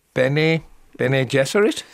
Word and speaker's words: Bene Gesserit: How do we say that rather mysterious group of nuns? Bene Gesserit, is that? – Oh, well, good for you.